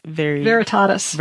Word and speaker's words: Veritatis: How do we pronounce that? De Arcanis Catholicae Veritatis. – Veritatis. Thank you.